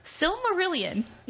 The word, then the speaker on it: Silmarillion: Silmarillion.